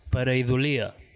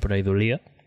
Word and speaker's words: Pareidolia: En castellà és pareidòlia, crec que... Però ho vam demanar a un erudit lingüista amic nostre que mos va dir que ho havien de pronunciar pareidòlia, ¿vale?